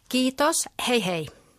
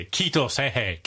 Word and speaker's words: Kiitos, hei hei: Kiitos, hei hei. – Oh, hey. This is easy. Kiitos, hei hei.